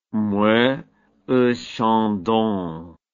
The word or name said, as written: Moët et Chandon